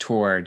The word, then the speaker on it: Tor: Oh, just Tor. Just Tor is perfect. Yeah, that's all good with me. Obviously, in America, it would probably be Thor because you would have the H in there.